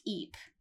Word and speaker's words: Ypres: She said it's pronounced Eep.